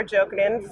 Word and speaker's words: Jokinen: Hi, I'm Cora Jokinen from Torq Masters Industries.